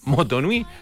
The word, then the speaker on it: Motu Nui: Moana responde un llamado del océano y por primera vez viaja más allá del arrecife de su isla de Motu Nui. ¿Lo dije bien? ¿Motu Nui? Sí.